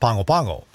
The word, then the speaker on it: Pago Pago: It looks like it's spelled Pago Pago, but in Samoan, it's pronounced Pongo Pongo.